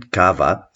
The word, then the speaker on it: Cava: C-A-V-A, in Spanish pronounced Cava because the V is pronounced like a B.